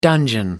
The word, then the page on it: dungeon: American English